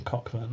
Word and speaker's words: Cockburn: You've been calling him Coburn all the way through. – I'm calling him Cockburn now, though. – Okay. – He might have pronounced it Coburn. – Yeah. – But everyone else pronounced it Cockburn.